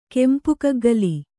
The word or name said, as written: ಕೆಂಪುಕಗ್ಗಲಿ